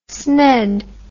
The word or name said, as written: sned